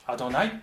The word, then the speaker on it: アドナイ: ゃダメだと思ってこれをアドナイっていうふうに発音したんですね、えー、言語のヘブル語の聖書でもこの 4 文字が書かれてあるところをそのまま読まずにアドナイっていうふうに発音した